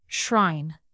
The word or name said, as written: shrine